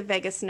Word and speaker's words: vagus: And where is the vagus nerve? And that's spelled, in case people want to look it up later, V-A-G-U-S. – Yes. Okay. Vagus nerve, not like Las Vegas, but yeah.